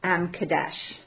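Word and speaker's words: M'kaddesh: But the spelling is much different than that. It's M-E-K-O-D-D-I-S-H-K-E-M. But the pronunciation was M. Kadesh.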